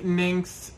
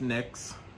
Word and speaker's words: Nyx: Anyway, so yes, technically, Nynx is pronounced Nyx.